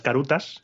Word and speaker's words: karuta: Entonces no puede decir carta, tiene que decir caruta, con K además.